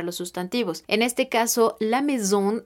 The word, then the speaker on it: maison: Cuando encontremos las vocales A y juntas, estas las estaremos pronunciando como si fueran una E normal en español. Algunos ejemplos serían la maison, que quiere decir la casa.